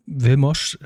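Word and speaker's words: Vilmos: Ja, der gute Mann, und ich versuche ihn richtig auszusprechen, heißt Wilmos Siegmond, wird er so ausgesprochen? – Wilmos, genau.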